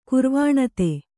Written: ಕುರ್ವಾಣತೆ

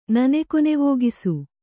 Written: ನನೆಕೊನೆವೋಗಿಸು

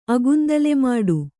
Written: ಅಗುಂದಲೆಮಾಡು

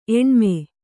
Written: ಎಣ್ಮೆ

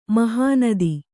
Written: ಮೆಹನತು

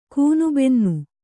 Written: ಗೂನುಬೆನ್ನು

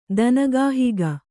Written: ದನಗಾಹಿಗ

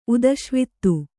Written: ಉದಶ್ವಿತ್ತು

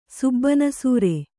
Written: ಸುಬ್ಬಾನಸೂರೆ

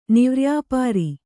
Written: ನಿವ್ರ್ಯಾಪಾರಿ

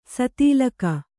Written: ಸ್ಥಲೀಕ

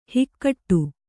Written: ಹಾಗಡ

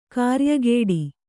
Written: ಕಾರ್ಯಗೇಡಿ

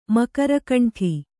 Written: ಮಕರಕಂಠಿ

